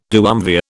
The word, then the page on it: duumvir: British English